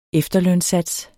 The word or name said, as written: efterlønssats